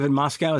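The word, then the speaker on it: Moscow: He must have been a little frustrated in this one because neither Christian Bale nor David Moskow, is that how you say it? – Yes. Yeah.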